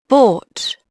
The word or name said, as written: bought